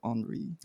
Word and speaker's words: Henri: I've been saying Henry. – Oh, but it's spelled with an I. I think you're supposed to say Henri.